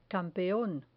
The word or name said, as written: campeon